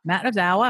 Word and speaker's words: Matt Ozawa: Matt Ozawa.